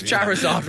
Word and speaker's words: Charizard: You're looking for a Chizzyard, Dave. I think that's one of the Pokemon goes. Charizard, right. Charizard.